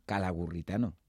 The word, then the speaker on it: Calagurritano: Calagurritano de honor, ¿lo he dicho bien? – Calagurritano, de la antigua Calagurris, porque Calahorra es una ciudad romana que se llamaba Calagurris y acuñaban moneda ya en aquella época.